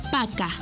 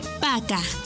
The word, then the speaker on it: Пока: P-A-Y-A-L-U-S-T-A. Пожалуйста. ¡Muy bien! Para finalizar, la palabra adiós se dice Пока. Пока.